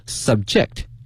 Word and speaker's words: subject: 这个词是呢 subject，但是这个发音发生了变化。我们知道 subject 作为名词啊，它表示一个科目啊，一个项目，但是今天它呢词性发生变化了，它的发音也随之发生了变化，叫 subject，